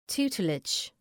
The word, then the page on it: tutelage: ‘tu:tlıdʒ